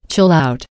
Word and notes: chill out: American English

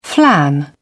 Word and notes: flan: British English

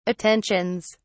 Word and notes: attentions: British English

